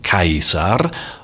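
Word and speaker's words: Caesar: Seine Nachfolger, die Nachfolger des Augustus, führten in der Folge diesen Titel selbst und auch der Name des Onkels des ersten Kaisers, nämlich Cäsar, damals vermutlich ausgesprochen Kaisar, wurde Teil des Kaisertitels.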